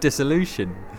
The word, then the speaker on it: Dissolution: Yeah, well, I call it Dissolution.